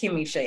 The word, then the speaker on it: Kimisha: Yes, that's correct. Kimisha, yeah.